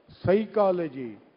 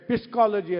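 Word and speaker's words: psychology: ಸೈಕಾಲಜಿ P ಏನಾಯಿತು ಹೇಗೆ ಬರೀತಾರೆ ಅದೇ ಥರ ಓದಬೇಕು ಅಂದರೆ ಅಥವಾ ಹೇಗೆ ಬರೆದಿರ್ತಾರೆ ಅದೇ ಥರ ಉಚ್ಚಾರಣೆ ಮಾಡಬೇಕು ಅಂತಂದರೆ ಅಲ್ಲಿ ಪಿ ಅಂದರೆ ಪಿಸ್ಕಾಲಜಿ ಅಂತ ಓದಬೇಕಾಗಿತ್ತು ಆದರೆ ಪಿ ಇಲ್ವೇ ಇಲ್ಲ ಸೈಕಾಲಜಿ ಅಂತಕ್ಕಂಥದ್ದು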